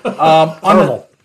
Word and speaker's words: Carmel: Carmel. It's like caramel Carmel.